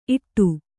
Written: ಇಡೆ

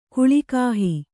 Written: ಕುಲಗೇಹ

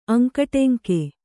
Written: ಅಂಕುಡೊಂಕು

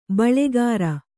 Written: ಬೊಳ್ಳುಗರೆ

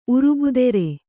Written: ಉರುಬುದೆರೆ

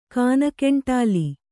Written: ಗಾಣಗಂಟಲ

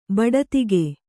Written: ಬಡತಿಗೆ

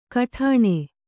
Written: ಘಟ್ಟಣೆ

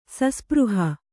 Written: ಸಸ್ಪøಹ

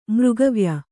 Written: ಮೃಗವ್ಯ

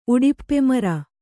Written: ಉಡಿಪ್ಪೆಮರ

